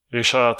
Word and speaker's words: Richard: Because he's French, so I'm going to pronounce it that way. So Risha tells you, like, get me my five, you know, golden leaves.